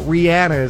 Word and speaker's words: Rihanna: Got to see Rihanna, not Rihanna. Rihanna is how you pronounce it.